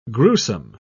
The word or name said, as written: gruesome